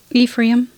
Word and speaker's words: Ephraim: Ephraim.